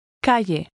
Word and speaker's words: calle: Pay attention to the sound of the double L, which is usually pronounced like an English Y. – Calle.